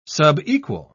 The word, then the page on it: subequal: British English